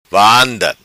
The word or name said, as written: vanda